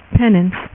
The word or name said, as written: penance